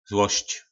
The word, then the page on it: zlosc: po nasimu